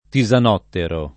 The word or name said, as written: tisanottero